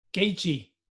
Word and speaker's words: Gaethje: Gaethje.